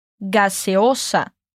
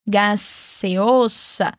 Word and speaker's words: gaseosa: Gaseosa. E nessa palavra, a gente tem duas vezes o sonzinho S. Aquele som do refri bem gelado abrindo. Gaseosa. É só lembrar disso para ficar mais fácil de pronunciar.